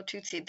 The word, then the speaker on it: Tutsi: Because I'm not sure whether you're Hutu or Tutsi. Am I saying it right? – It is Hutu or Tutsi.